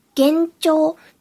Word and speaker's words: 玄鳥: 今回のツバメ来たるのツバメの漢字表記は、玄米の玄に鳥と書いています。幻鳥と読むツバメの別名とのことです。